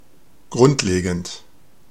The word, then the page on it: fundamenteel: [fyn.da.mǝn.teːl]; /fyn.da.mǝn.te:l/